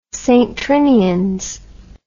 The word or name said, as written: St Trinians